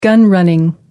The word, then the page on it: gun running: American English